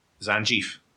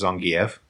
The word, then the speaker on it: Zangief: I've said Zangief my entire life because I've lived in the central belt of Scotland. I'm an ignorant bastard. I know it's pronounced Zangief, but I'm going to start making the effort to start saying that.